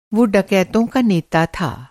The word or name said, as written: डकैत